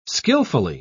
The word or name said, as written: skillfully